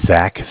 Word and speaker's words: Zach: My name is Zach, spelled Z-A-C-H. – Z-A-C-H, uh-huh.